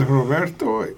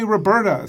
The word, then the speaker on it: Roberto: In the U.S., they called me Bert because Americans have a hard time saying Roberto. They just couldn't pronounce it. Amer- Americans. I, they could. They'd keep calling me Roberta, so I finally gave up.